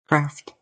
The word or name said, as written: Craft